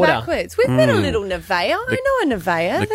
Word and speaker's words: Nevaeh: The heaven thing, the heaven spelled backwards, it's pronounced Nevaeh.